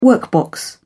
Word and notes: workbox: British English

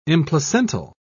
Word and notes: implacental: British English

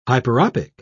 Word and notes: hyperopic: British English

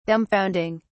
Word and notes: dumbfounding: British English

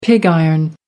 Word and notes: pig iron: American English

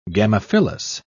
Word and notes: gamophyllous: British English

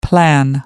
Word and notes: plan: American English

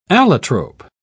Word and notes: allotrope: British English

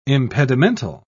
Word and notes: impedimental: British English

